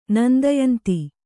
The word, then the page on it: ನಂದಯಂತಿ: Kannada